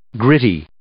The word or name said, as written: gritty